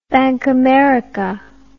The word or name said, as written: bankamerica